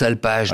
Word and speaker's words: Alpage: Vous le prononcez comment, vous ? – Moi, je prononce Alpage. Parce qu'il veut jouer le jeu de mots français, en fait.